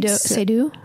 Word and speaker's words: Seydoux: Seydoux.